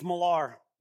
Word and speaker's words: Millar: It's Millar, right? It's just like Miller, except you spell it with an A instead of an E, right?